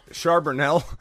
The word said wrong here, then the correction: Charbonnet: Charbonnet? I think it's Charbonnet is how you pronounce it.